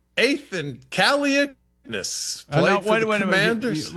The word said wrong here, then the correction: Kaliakmanis: You dropped out in the last. Pronounce Kaliakmanis. – Kaliakmanis, I think.